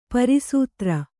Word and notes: ಪ್ರಸ್ತರ: prastara

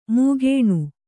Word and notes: ಮಾಗಣಿ: māgaṇi

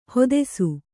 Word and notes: ಹೊದೆಸು: hodesu

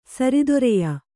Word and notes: ಸರಿದೊರೆಯ: sari doreya

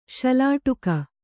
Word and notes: ಶಲಾಟುಕ: Kannada

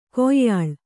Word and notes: ಕೊಯ್ಯಾಳ್: kannada

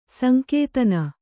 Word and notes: ಸಂಕೇತನ: Kannada

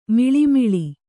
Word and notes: ಮಳಮಳ: Kannada

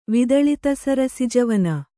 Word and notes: ವಿದಳಿತಸರಸಿಜವನ: vidaḷita sarasija

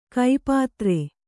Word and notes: ಕೈಪಾತ್ರೆ: kai pātre

